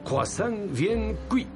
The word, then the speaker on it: croissant bien cuit: croissant bien cuit.